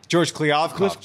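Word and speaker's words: George Kliavkoff: George Kliavkov, John.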